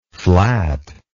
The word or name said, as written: flatness